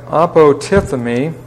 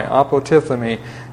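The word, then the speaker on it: apotithemi (ἀποτίθημι): Here we have apotithemi, and that's a long E, A-P-O-T-I-T-H-E, that's a long E, M-I, apotithemi.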